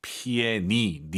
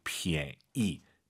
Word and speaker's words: 便宜: 피에니, 니은 발음 들리면 안 돼요. 피에, 이, 피에이, 피에이. 오히려 그 피엔 발음에서 엔, 니은 발음이 약간 무금된다는 느낌으로 피에이. 이것도 좋을 것 같습니다. 피에니, 이렇게 하지 마시고요. 피에이.